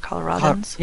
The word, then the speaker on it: Coloradans: I think we're all reeling right now from this, and obviously Coloradans are, is that how you say it, Coloradans? – Yes, I believe so.